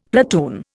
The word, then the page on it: platoon: Indian